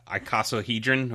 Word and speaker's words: icosahedron: I had to look up how to pronounce this. – Icosahedron or icosahedron.